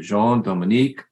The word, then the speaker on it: Jean Dominique: Today's guest is Mr. Jean Dominique Andreu.